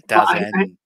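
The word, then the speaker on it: DAZN: Is it Dazen? – Yeah, I call it Dazen. – Yeah, Dazen.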